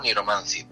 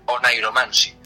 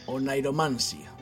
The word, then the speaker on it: Oneiromancy: Vale, mira, yo que soy así de pronunciar las cosas como me cuadran, digo Niromancy, pero descubrí cuando le mandé la introducción a mi colega de Estados Unidos que se pronuncia Onairomancy.